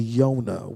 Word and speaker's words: Yonah: The correct pronunciation of Jonah's name in the Hebrew is actually Yonah, which simply means the dove.